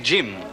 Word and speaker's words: Jim: Il faut prononcer Jim à l'anglaise avec un « de » devant.